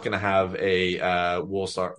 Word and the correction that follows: Walsall: I can't pronounce it. You pronounce it for me, please. Oh my God. – Walsall. – There we go.